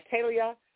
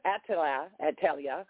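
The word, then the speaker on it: Athaliah: And again, you can pronounce these names two ways. Some people said they ain't correct now. God looked these names up. Attila, Attalia, you can pronounce her name two ways because they mean they have a pronunciation.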